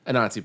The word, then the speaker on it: Anansi: You pronounce it now. Now okay. that we've clarified, the word Nazi is not in it. – Okay. Anansi boy.